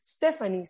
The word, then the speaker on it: Stephanie: Entonces digo, Stephanie, se escribe como Lazy Town.